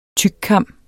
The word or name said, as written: tykkam